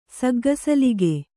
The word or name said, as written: ಸಗ್ಗಸಲಿಗೆ